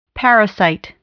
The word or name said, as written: parasite